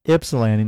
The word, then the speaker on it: Ypsilanti: Well, I've always said Ypsilanti, and Dana corrected me one day. She's like, it's Ypsilanti, not Ypsilanti.